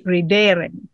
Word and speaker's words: ridere: And then it would be ridere. – Ridere with a long E-R-E.